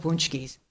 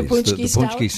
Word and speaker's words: paczki: We're out of punchkis. The lines are yep. – down the street. – Springsteen tickets ain't nothing compared to lines for punchkis. – Yes. Spelled P-A-C-Z-K-I, pronounced various ways.